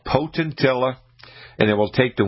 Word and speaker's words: Potentilla: It's called Potentilla, and it's spelled P-O-T-E-N-T-I-L-L-A, Potentilla.